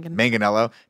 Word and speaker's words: Manganiello: Manganiello to say like three lines.